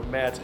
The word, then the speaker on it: Mads: So Mads, M-A-D-S, that's my first name, not short for anything, that is my actual name, M-A-D-S.